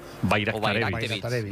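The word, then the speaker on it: Bajraktarević: Bayraktarevic. O sea, la J la conviertes en una I, Bayraktarevic.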